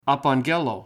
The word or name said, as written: apaggello